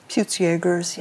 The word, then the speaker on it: Peutz Jeghers: So, one of your many adventures that I don't want to ignore is uh, that with this lifelong experience with, do you say Peutz Jeghers? Is that how you say it? – Peutz Jeghers, yeah.